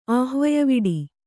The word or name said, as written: ಆಹ್ವಯವಿಡಿ